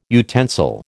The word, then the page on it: utensil: American English